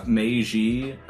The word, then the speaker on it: Meiji: So real quick note on the pronunciation, because Nick was asking, Meiji, M-E-I-J-I, right? – Mm-hmm.